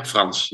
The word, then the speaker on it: Frans: Yeah, Frans, yeah.